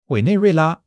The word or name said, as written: Wei3 nei4 rui4 la1